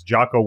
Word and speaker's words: Jocko: I have my you just call him Junko. – Is it Junko? Is it Jocko? How do you say his name again? – Junko's your garbage man. Jocko. J-O-C-K-O.